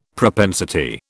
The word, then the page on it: propensity: British English